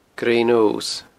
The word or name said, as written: Grey Nose